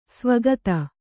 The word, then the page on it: ಸಾವಕಾತಿ: sāvakāti; Kannada